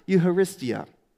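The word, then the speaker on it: eucharistia (εὐχαριστία): In fact, he wrote in the Greek language, and he wrote that word like this, which you pronounce eucharistia.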